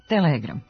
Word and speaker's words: telegram: zabeleženi oblici telegram i telegraf sa kratkosilaznim akcentom na prvom slogu kao dubleti sa starijim izgovornim varijantama.